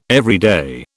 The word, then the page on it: everyday: British English